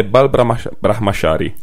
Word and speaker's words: Bal Brahmachari: Il film in questione è Bal Brahmachari, giusto? – Sì.